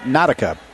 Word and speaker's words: Natika: N-A-T-I-K-A, but they pronounce it Nautica.